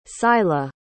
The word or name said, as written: sila